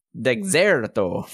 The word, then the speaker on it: Dexerto: Uh, and they've been running into some hot water uh, there's an article that came out uh, this week from Dexerto. I don't know how to even pronounce that. No, correctly. that sounds about right. – Dexerto from two days ago.